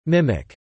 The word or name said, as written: mimic